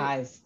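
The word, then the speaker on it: Eysz: Today's episode, we have from, is Eyes? Am I saying it right? – Eyes, that's right.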